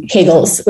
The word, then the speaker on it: Kegel: in which case doing Kegels, which I learned is Kegel that rhymes with bagel.